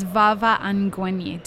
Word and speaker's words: Vava Angwenyi: And joining me for this episode of the podcast is Vava Angwenyi.